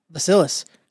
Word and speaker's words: Vasilis: So that brings me to today's episode, episode 139 with Vasilis.